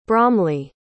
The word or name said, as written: bromley